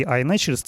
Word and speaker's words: iNaturalist: Да, ну вот эта программа iNaturalist, да, вообще так правильно произносится,